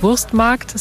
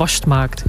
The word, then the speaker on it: Wurstmarkt: Ich komme zwar gebürtig aus der Pfalz, spreche aber wenig pfälzig, aber dass man natürlich nicht Wurstmarkt sagt, sondern das ist unser Wurstmarkt.